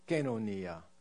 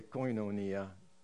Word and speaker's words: koinonia: The correct way to say it is actually kenonia, but we usually say koinonia to anglicize it.